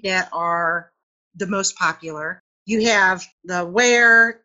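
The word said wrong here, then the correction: Warré: and I'm not saying that right. I never do say that right. It's Warre, I'm aware.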